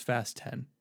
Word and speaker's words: Fast X: We've been calling it Fast X, but YouTube, or not YouTube, Wikipedia is quick to clarify it's pronounced Fast 10, which I understand why, but I still like Fast X.